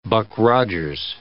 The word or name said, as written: Buck Rogers